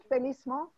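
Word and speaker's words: félicement: F-E-L-I-C-E-M-E-N-T. – Felicimons, I don't know. How do you speak ancient French? – It's pronounced Felicimons, and it means happy or okay.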